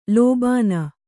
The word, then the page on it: ಲೋಭಾನ: lōbhāna